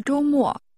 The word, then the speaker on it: 周末: 这个周末。周末。周末。 – Weekend is pronounced with a high level tone, followed by a falling tone. – 周末。再说一次。 – Weekend. – 周末。 – And how would you say this week? – 这个星期。星期。 – 再说一次。 – Weekend。 – 周末。周末。 – 周 is a different word for week. Try to say this weekend. – 这个周末。